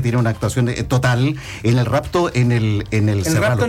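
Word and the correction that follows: serrallo: Entre Cerrallo y Cerralo, ¿ah? – Cerrallo. – Se pronuncia Cerrallo.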